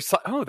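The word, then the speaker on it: CICO: Psycho. – That's a good way to pronounce it. Psycho, yeah. C-I-C-O. I was thinking Kaiko, Waiko, Siso, but Psycho. That's a great acronym because I believe it is pretty psychotic.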